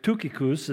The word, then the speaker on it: Tychicus: But like the letter to the Philippians, it was delivered by, remember, Tychicus, or Tychicus, as it's pronounced correctly, and also by a man by the name of Onesimus.